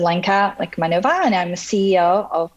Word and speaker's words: Lechmanova: And her name is Lenka Lekmanova. Did I pronounce that last name correctly? – Yes, you did.